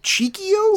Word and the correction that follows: Chico: Same, I don't know. I think well because it's Chico.